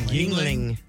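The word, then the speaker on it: Yuengling: New texter texted and said I never know how to pronounce this beer. Ling Ling? – Yingling. Yeah.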